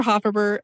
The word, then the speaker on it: Hofferber: So my name is Jennifer Hofferber.